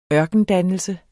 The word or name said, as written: ørkendannelse